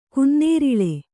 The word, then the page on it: ಗನ್ನೇರಳೆ: kannada